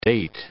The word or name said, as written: date